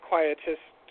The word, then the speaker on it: Quietus: We're saying it wrong. I found it online, and it's quietus.